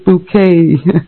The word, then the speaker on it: Bucket: Hyacinth Bucket, but she pronounces it bouquet.